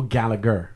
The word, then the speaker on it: Gallagher: No, so what it is, the English way of pronouncing it varies. So Gallagher is generally how like English people will say it. – Okay. – I'm from an Irish family. – Ah. – So if you say Gallagher in an Irish accent, it comes out as Gallagher. – Oh, okay. – So it's Gallagher, technically. – Okay. – Yeah. And the Scottish spell it with a C. So it's G-A-L-L-A-C-H-E-R. And yeah, that's probably how it's pronounced Gallagher as well.